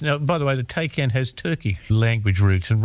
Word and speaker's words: Taycan: I might have said a Tay, but it's not that, is it? – No, it's a Taycan. – Well, T-A-Y-N.